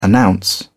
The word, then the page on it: announce: British English